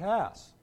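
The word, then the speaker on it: Hass: Among writers, of course, it's very confusing because we have Robert Haas, or is it Haas? It's Robert Haas.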